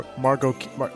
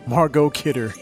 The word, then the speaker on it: Margot Kidder: I say Margaret Kiddo. – Margot Kidder. – I butchered her name. Margot Kidder.